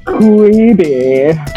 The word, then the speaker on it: Quibi: You're making it more fancy than it is. – Quibi. – It's Quibi.